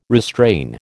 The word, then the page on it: restrain: American English